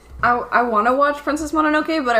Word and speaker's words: Mononoke: About Princess Mononoke now. Sorry. – It's not. Here, let me show you. – Mononoke? Sorry, I mispronounced.